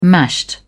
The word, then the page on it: mashed: British English